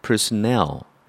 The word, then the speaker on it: personnel: 발음을 조심하셔야 되는데요. personnel, 넬의 강세가 있습니다.